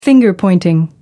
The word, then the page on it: finger-pointing: American English